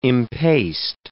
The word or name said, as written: impaste